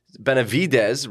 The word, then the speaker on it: Benavides: Domingo Benavides.